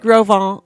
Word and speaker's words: Grosvenor: It was an awesome route through Yellowstone, Teton National Park, the little-known Grosvenor, it's pronounced Grosvenor Range, François Tapon.